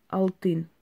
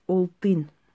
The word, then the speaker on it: Олтин: Сочилган Алтын. Олтын. Можно Олтын. Потому что на узбекском, в принципе, очень много букв А произносится как О. Ну, особенность языка.